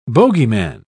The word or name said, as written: bogeyman